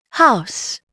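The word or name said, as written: house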